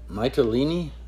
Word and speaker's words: Mytilene: Mytilene? That E on the end is a long E.